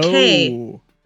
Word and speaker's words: Takei: Takei. Because it rhymes with gay and it's okay to be Takei.